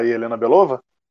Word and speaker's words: Helena Belova: Que é a Helena ba é, Bakova que fez a Viúva Negra agora. A irmã a, da ah. Viúva Negra. A Helena Belova? – Belova, isso, eu falei errado.